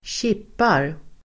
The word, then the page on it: kippar: [²tj'ip:ar]